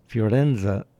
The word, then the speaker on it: Firenze: Firenze is actually the Italian pronunciation for Florence.